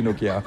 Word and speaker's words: Nokia: Mais il faut pas accentuer la prononciation de chaque lettre. – Non. – Nokia. Non. – On pourrait, mais c'est bon aussi Nokia.